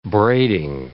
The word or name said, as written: braiding